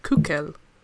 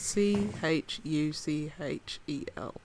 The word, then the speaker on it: Chuchel: But the newest game is called, I think it's pronounced Kukel. In Czech, it's spelled C H U C H E L. a lot of people me saying Chuchel, but I think the correct, in inverted commas, because I don't speak Czech, pronunciation.